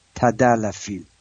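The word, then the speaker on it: tadalafil: تدالفیل، T-A-L-A تدالفیل. البته فراموش نکنم اسم اون ویاگرام هست سیلدنافیل، S-I-L سیلدنافیل، که دوستان بودن که چطور اینو تلفظ کنم.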